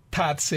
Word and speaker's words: Tatse: Tatse Restaurant.